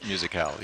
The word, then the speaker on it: Musicale: Well, it's always wonderful to have you here on the Santa Fe Radio Cafe and the Midwinter Musicale, how do you say it? – Musicale, yes.